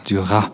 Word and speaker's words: Duras: And some say Duras, but I really have to check that out, because I'm pretty sure it's Duras and that you don't pronounce the S.